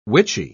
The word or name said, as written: witchy